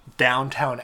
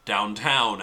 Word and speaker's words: Downton: Downton Abbey, the new era. – First off, he's pronounced it wrong. Just like everyone in the United States pronounces it wrong. It's Downton, not Downtown Abbey.